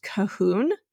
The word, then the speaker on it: Kahun: She mentioned that in the town of, I believe it's pronounced Cahun, K-A-H-U-N, in about 2000 to 1600 BC,